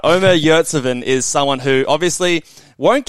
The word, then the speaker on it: Omer Yurtseven: And that is, I'm going to butcher this name, Callum, but that is Omer Yurtseven. Did I get that all right? Did I do all right there? – I think that was good.